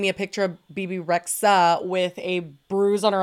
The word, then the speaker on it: Rexha: I think it's just Bibi Rexha. I think it's like a silent H.